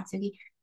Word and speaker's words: Kintsugi: Kintsugi? I think so. Yeah. Kintsugi.